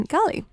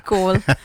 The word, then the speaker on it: Galle: So India playing the second test against Sri Lanka in Colombo after winning the first one in Galle. – Goal.